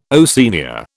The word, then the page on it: Oceania: British English